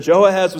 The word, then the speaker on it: Jehoahaz: Before Jehoiakim, there's a guy named Jehoahaz.